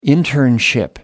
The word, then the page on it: internship: American English